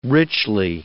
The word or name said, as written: richly